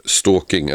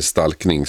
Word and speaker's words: stalkning: Vi pratar om stalking eller stalkning som det korrekta svenska uttalet egentligen är.